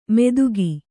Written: ಮೆದುಗಿ

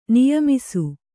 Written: ನಿಯಮಿಸು

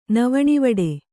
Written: ನವಣಿವಡೆ

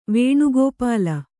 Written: ವೇಣುಗೋಪಾಲ

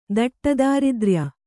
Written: ದಟ್ಟದಾರಿದ್ರ್ಯ

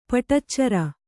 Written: ಪಡಿಚರ

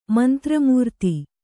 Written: ಮಂತ್ರಮೂರ್ತಿ

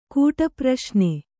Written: ಕೂಟಪ್ರಶ್ನೆ

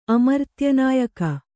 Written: ಅಮರ್ತ್ಯನಾಯಕ

